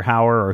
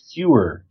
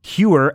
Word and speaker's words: Huer: Yes, then they capture Buck and Buddy and Doctor Hauer or Hoover or however you say his name. Huer. Huer, and they also discover that these mutants are collecting a bunch of nuclear bombs.